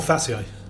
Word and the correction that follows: Fazio: Federico Fazio. I pronounced it wrong.